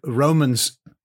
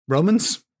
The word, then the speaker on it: romance: Yes, it is a chivalric romance. Romance? Or romance is another way of pronouncing that word.